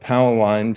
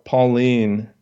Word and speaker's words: Powelline: Putting these Powelline pieces together, and I get the pronunciation of Powelline from Oliver Crisp, because to call it Pauline is just confusing.